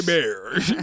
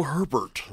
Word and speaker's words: Hebert: It's me, Kyle Bear. Send me your money. – I'm Kyle Herbert. I'm like, oh, we know that's not right. – Yeah, Herbert. – You're not pronouncing it right. H-E-B-E-R-T. That's Louisiana French.